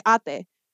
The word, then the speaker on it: Ate: Like, so her older cousin Bernadette, you know, it looks like it's eight, right? It's spelled A-T-E. And I know that would be an issue. So like, no, it's pronounced Ate.